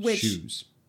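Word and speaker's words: SHU: That's capital S, capital H, capital U. – Shoes. – Huh? – It's oftentimes pronounced shoes.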